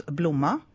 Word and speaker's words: blomma: If you're talking about one flower, it's blomma.